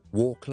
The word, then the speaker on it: walk: 另外当然 walk 啦，行路啦，W-A-L-K，嗰个 L 亦都系唔读嘅，walk。好，所以 silent L 我哋有 talk，我哋有 half，我哋有 salmon，我哋有 almond in British English and walk。